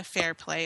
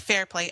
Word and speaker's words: Fairplay: I also am on Twitter, so if anybody wants to contact me, I am Jenna Fairplay on Twitter. – Okay, you want to spell that one out? – Oh, J-E-N-N-A for Jenna, and then last name is, Fairplay, F-A-I-R-P-L-A-Y.